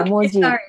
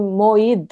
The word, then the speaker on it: Moji: Yeah, Moji. Yeah. In Finland, I became Moid though, because J is pronounced like Y.